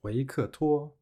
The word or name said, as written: 维克托